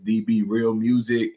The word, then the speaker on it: DB Real Muzik: Once again, you know, my name is DB Real Music. You know, it's spelled M-U-Z-I-K. DB Real and then M-U-Z-I-K.